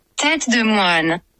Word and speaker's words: Tête de moine: Tête de moine.